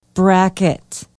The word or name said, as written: brackett